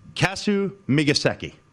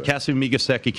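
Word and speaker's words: Kasumigaseki: It is Kasu Migaseki. – I think that's right. That's what I was going to say. – Kasu Migaseki Country Club.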